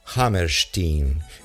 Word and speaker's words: Hammerstein: que los americanos, como ustedes saben, pronuncian Hammerstein.